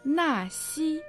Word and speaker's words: Наси: Наси, на произносится в четвертом тоне, а си в первом.